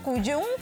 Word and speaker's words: Cuyún: Arroba Claudia Cuyún. Mi apellido se escribe C-U-Y-U-N.